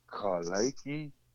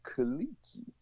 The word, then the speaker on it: Kelechi: My full real name is pronounced Kalaiki Javay or Javine. In America, they pronounce it Kalichi. – Yeah. – Kalichi. But in the African language of Tree, in West African language of Yoruba, is the E changed to an A sound, like ka, and you can be la or lay.